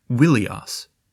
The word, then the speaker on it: Ilios: Let's just say that one Greek name for Troy was Ilios, but they originally pronounced Ilios with a W, saying it Wilios, not far at all from the Hittite name Welusha.